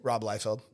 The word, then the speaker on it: Liefeld: Well, I was actually just, I just watched a video about Rob Liefeld. Is that how you say it? – Rob Liefeld. – Liefeld.